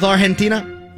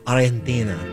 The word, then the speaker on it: Argentina: Is it a soft G with Argentina? – Yes, it's almost like the G isn't even there. Argentina. Oh. – I think you got to roll the R too.